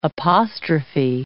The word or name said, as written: apostrophe